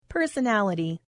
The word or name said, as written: personality